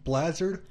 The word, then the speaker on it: Blazard: Blazard rhymes with hazard, all you people out there seeing this written down.